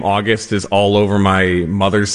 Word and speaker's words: August: August Financial Planning. And thanks for having me, John. – As in the month, not as in the exaltation.